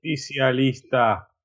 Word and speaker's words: Justicialista: Justicialista.